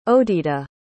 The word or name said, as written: odeda